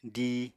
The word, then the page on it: díi: apatani